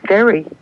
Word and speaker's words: Terry: Jerry, is that your name? – No, my name is Terry.